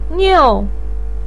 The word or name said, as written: niu4